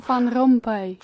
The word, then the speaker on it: Van Rompuy: se tiene que pronunciar como Herman Van Rompuy o algo parecido. – Van Rompuy. – Van Rompuy.